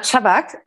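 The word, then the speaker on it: Chubbuck: Ich habe ein Interview von dir gelesen, da sagst du, dass dich Ivana Chubuk, habe ich das richtig ausgesprochen? Ivana Chabak, ja.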